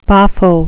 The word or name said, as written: boffo